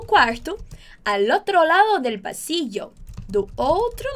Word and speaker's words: al otro: Cuidado com a pronúncia. Al otro... Al otro, não ao outro. Al otro, junto L com o.